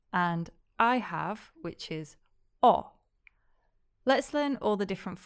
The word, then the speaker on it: ho: The H is always silent in Italian, but learners often make the mistake of pronouncing it. Just remember that I have is o. So if you sound like Santa, ho ho ho, that means you're saying it wrong.